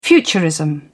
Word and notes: futurism: British English

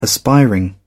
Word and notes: aspiring: British English

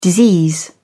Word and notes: disease: British English

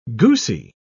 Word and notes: goosy: British English

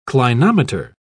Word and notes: clinometer: British English

